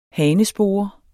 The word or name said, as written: hanespore